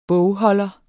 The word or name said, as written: bogholder